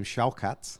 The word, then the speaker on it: Shavkat: Wait till you see his sit down for TNT Sports with now I've got to call him Shalkat because that's how you pronounce it. Shavkat Rakhmonov.